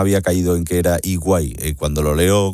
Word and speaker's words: EY: Lo voy a hablar con don José Luis Rojo, que es experto y socio de ciberseguridad de EY, se pronuncia EY, o sea, es EY,